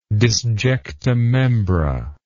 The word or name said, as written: disjecta membra